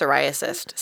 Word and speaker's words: Cyriacus: The king, being thoroughly fascinated, sent Surgeon Psoriasis, that has to be pronounced psoriasis, C-Y-R-I-A-C-U-S, psoriasis, Surgeon Psoriasis Ehlers to Guildford.